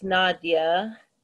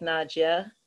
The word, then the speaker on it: Nadia: But the thing with my name, it's like, it's Nadia. – Right. – But, in Russia, like my Russian relatives, it would be pronounced more like Nadia.